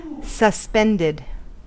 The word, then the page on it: suspended: American English